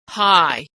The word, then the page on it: high: gh